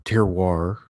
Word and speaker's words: terroir: Dave and I have been hearing a lot recently about the term terroir. I hope I am pronouncing it correctly. – You are. – And, you know, good. Otherwise, it sounds like terror.